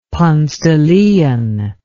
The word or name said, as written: ponce de leon